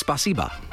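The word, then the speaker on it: spasiba (спасибо): But for the first three days, I'd been going to because I wrote it phonetically in my uh, phone. i had Yeah. been going too heavy on the e bit of that, so i had been going spasiba, ah, right.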